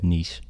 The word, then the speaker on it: niche: I think there's some industries that are very, very, very niche. How do you say it? – Niche. – Whatever. I say, yeah. – I say niche. I'm a Midwesterner. – Both are right.